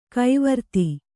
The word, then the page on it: ಕವರ್ತೆ: kavarte